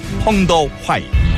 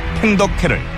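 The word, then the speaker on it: 펑더 화이 (彭德怀): 그리고 휴전 협상이 지연된 결정적 원인은 바로 이 사람한테 있었으니 1953년 7월 27일 휴전 협정에 서명한 중국군 사령관 펑더 화이 한국 발음으로 팽덕회를 이번 주에 그분으로 모셨다.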